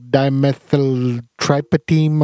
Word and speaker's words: dimethyltryptamine: It's called, like, dimethyltryptamine or something like that, right?